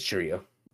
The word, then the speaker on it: Chourio: Like, why, or not that you need to reward teams by default for that, but like if Jackson Chourio, or Chourio, did I say that right? I think so. He's on the Brewers. Anyways, I think it's Chourio. I don't think there's an S at the limit.